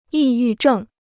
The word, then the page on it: 抑郁症: yì yù zhèng